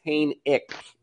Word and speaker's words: Hainick: He actually corrected me. It's Hainick. With no silent H.